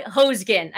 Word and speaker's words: Hoesgen: So please help me welcome our amazing guest today, Ty Hoesgen.